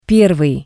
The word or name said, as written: Pervii